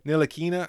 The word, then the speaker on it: Ntilikina: I think that T is silent. Ntilikina.